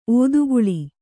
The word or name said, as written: ಒತ್ತೆಗೊಳ್ಳು